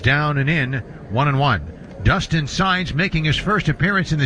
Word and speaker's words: Saenz: Now, the new pitcher, lefty Dustin Saenz, S-A-E-N-Z, pronounced Saenz, and his fastball at 93 swung on a missed strike one to Joey Meneses.